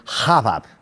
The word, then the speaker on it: rabab: E o que é que é isto da Abab? Abab, o João uh, uh, pronunciou mal a palavra, não é? Então. Que é rabab.